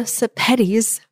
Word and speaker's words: Sepetys: And also, go check out Ruta Sepetys' books. And let's see if I can remember how to say that. I liked that she said it rhymes with spaghettis.